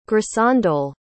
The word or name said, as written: grisandole